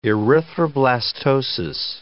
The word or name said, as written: erythroblastosis